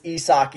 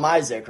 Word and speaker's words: Isak: I mean, dude, for what it's worth, Chaz, I didn't know it was Isak either. You know, a guy's name is spelt like Isaac. You're going to call him Isaac, right? I mean, that's just what it is.